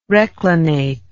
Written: reclinate

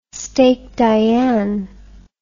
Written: steak Diane

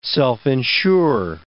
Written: self-insure

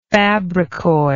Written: fabrikoid

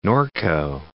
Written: Norco

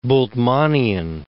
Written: Bultmannian

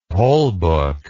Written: pollbook

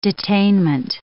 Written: detainment

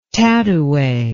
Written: tatouay

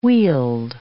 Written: wheeled

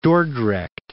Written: Dordrecht

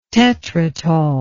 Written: tetrytol

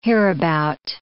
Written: hereabout

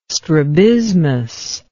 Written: strabismus

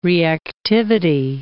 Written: reactivity

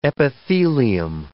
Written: epithelium

